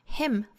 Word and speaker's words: hymn: 那回过头我们再来看这首歌的歌名啊， Him for the Weekend。 Him 赞美诗 H-Y-M-N 这个单词的发音呢，和 H-I-M Him 的发音是完全相同的，所以我们要知道这个单词的字母 N 是不发音的，然后字母 Y 呢是读成短音松音的 I。